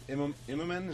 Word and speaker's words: Immonen: Hey, Brian, I wanted to ask you about Ultimate Spider-Man with Stuart Immonen.